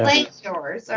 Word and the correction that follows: Langoliers: or how do you say that? – Kirk Cameron only makes Christian movies. – Langoliers. Is that right, Don? – Yeah.